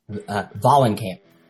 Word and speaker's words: Vahlenkamp: Vahlenkamp.